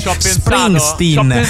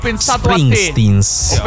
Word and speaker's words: Springsteen: E per la prima volta Matteo Cillario pronuncia esattamente Springsteen, senza il gerundio o il genitivo sassone. Springsteens.